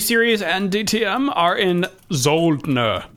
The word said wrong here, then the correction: Zolder: Zoldner. Oh, there's no N there. It's just Zolder.